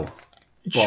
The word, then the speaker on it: Bhaal: He looks B-H-A-A-L. – Ball. Sure. – Yeah. – Yeah. No, that's how you pronounce it, but that's how you spell it.